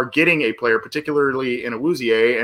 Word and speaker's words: Awuzie: The pronunciation is Awuzie, so okay.